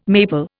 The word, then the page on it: maple: Indian